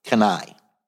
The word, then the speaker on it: CANI: I've been around a long time, so I use a phrase called kanai. It's spelled C-A-N-I, kanai.